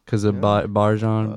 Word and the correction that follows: Bijan: Bijan. We'll get his name right by the end of the year.